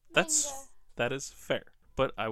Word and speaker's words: manga: It's manga. – Well, us Americans like to say manga, but it's manga because it's a Japanese word. They have ma-ga. Manga.